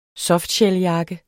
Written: jakke

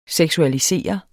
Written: seksualiserende

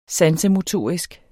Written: sansemotorisk

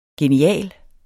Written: genial

